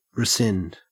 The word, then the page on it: rescind: Australian English